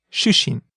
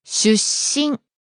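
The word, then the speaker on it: shushin (出身): It's not shushin, short vowel, but it's shushin. – There's a slight pause in there.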